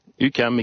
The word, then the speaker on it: UQAM: UQAM, exactement.